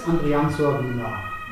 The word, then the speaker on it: Andriantsoavina: Heute mit dem spannenden Menschen Felix Andrian Sorbina.